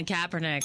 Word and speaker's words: Kaepernick: Colin Kaepernick. – Kaepernick is the correct pronunciation.